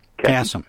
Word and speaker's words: Kasem: And I'm sorry, I mispronounced his name earlier. – Kasem. – And I'm terrible with names, Mike, so thanks for, okay. thanks for that correction.